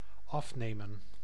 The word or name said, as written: draw